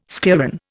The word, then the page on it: schlieren: Indian